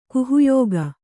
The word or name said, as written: ಗುಹ್ಯಕ